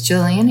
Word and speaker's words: Gillian: It's Gillian Anderson.